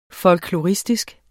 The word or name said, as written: folkloristisk